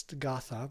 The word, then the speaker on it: gatha: You may not even heard this term, G-A-T-H-A. It looks like it's gatha, but it's pronounced gatha.